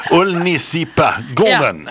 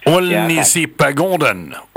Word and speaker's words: Ullnisipagården: Jag ska säga att jag uttalar det här rätt. Men jag påstår att Ullnispagården. – Ullnisipagården. – Ja. Som Gustav Vasa övernattade på finns i Mora. – Vi tar det en gång till. Ullnisipagården.